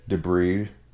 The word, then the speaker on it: debris: Debris. – Debris. There's no, the S is silent.